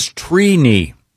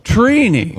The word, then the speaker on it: Trini: The correct pronunciation is Trini. – Wow. – Trini. – Like T-R-E-E. – Like weenie. – Yeah, I and so is it phonetically T R E E K N I? – Yeah, it's, phonetically it is T R E E.